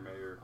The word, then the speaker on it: Mayer: It's just M-A-Y-E-R instead of M-E-Y-E-R. – Yeah. – M-A-Y-E-R. – Mm-hmm. – Mayer? – Yeah. Oscar Mayer. – Oscar Mayer Wieners. – It's pronounced Mayer, though.